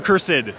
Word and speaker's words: Cursed: Just uh, type in the few and cursed and is it cursed or cursed? – It's uh, the few and cursed. Cursed, okay. Yeah. All right, so cursed is the correct pronunciation.